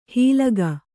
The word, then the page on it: ಹೀಲಗ: hīlaga